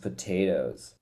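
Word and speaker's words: Potoooooo: So what it's supposed to be he is best known for the unusual spelling of his name pronounced potatoes. So the way it's supposed to, his name's supposed to be potatoes, but they spelled it P-O-T-O-O-O-O when they really meant to do P-O-T, the number 8, and then O.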